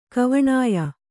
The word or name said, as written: ಕವಣಾಯ